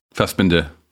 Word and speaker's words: Fassbinder: It's a movie from the early 1970s, um, a Fassbinder movie. Did I say that right, guys? – Fassbinder.